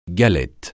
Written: galette

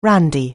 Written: randy

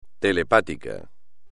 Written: telepático

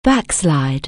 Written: backslash

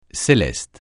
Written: céleste

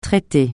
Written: traité

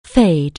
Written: fade

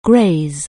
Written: graze

